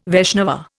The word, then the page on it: Vaishnava: Indian